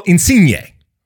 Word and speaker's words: Insigne: And Lorenzo Insigne.